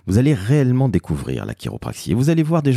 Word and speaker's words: chiropraxie: le site de l'Association française de chiropraxie. Chiropraxie, ça s'écrit comment Eh bien c'est très simple, C-H-I-R-O-P-R-A-X-I-E. Et oui, ça se prononce quelque part à l'américaine.